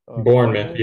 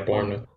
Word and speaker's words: Bournemouth: Bournemouth, yeah, Bournemouth.